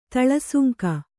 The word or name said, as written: ತಳಸುಂಕ